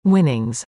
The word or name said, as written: winnings